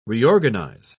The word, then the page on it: reorganise: British English